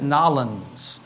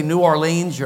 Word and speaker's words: New Orleans: And you pronounce it Nolens. That's the proper pronunciation, Nolens. If you're from there, if you say New Orleans, you're not from there. If you say New Orleans, that's 3 syllables. It's 1 syllable, Nolens. Maybe it's 2 syllables.